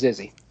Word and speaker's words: Izzy: So if you don't know how to pronounce my name, I prefer you use Izzy.